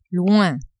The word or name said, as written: loin